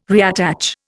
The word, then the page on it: reattach: Indian